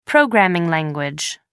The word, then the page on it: programming language: American English